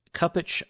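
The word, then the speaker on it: Cupich: Blaise Kupak of Chicago. Kupich, I can never pronounce that name.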